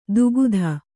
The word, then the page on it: ದುಗುಧ: Kannada; dugudha